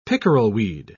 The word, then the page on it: pickerelweed: British English